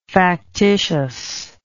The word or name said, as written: factitiousness